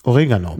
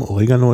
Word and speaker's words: Oregano: Wir sagen im Deutschen immer Oregano oder Oregano. Viele. Oder Origano, weil es auf Lateinisch tatsächlich Oregano heißt, das ist eigentlich ein griechisches Wort. Origanos.